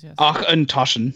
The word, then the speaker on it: Auchentoshan: Auchentoshan.